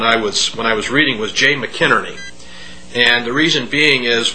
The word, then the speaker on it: McInerney: Well, Jay McInerney, he pronounces it McInerney. – McInerney, okay.